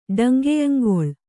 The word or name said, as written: ಡಂಗೆಯಂಗೊಳ್